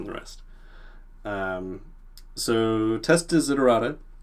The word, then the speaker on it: Desiderata: So, as someone who's always trying to improve their own testing skills, can you tell me a little bit about Test Desiderata and if I pronounce that correctly? – Uh, yes, you, you got it.